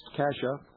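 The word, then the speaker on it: Cascia: Raised as a strict Roman Catholic, Keating was graduated from Cassia Hall High School, pronounced Cassia, an all-male private Catholic school.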